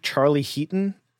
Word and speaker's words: Charlie Heaton: Catherine Keener is in it, and am I getting his name right? Charlie Heaton? Is that his name? – Yeah. He's a British actor, yeah? – Yeah.